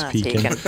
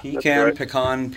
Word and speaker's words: pecan: Apparently no one can agree on how to pronounce that particular word. – Pecan, yeah. – Pecan, pecan. I say pecan, but the real hardcore Southerners in Georgia definitely say pecan.